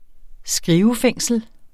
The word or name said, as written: skrivefængsel